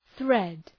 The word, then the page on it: thread: ɵred